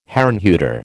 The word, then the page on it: Herrnhuter: American English